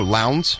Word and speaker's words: Lowndes: Jessica Lowndes. – How do you say her name? 902 Lowndes. – Lowndes. – Lowndes. L-O-W-N.